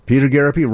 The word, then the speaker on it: Garapi: Peter Garapi rhymes with therapy.